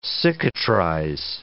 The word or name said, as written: cicatrize